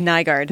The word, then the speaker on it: Nygaard: Nygaard.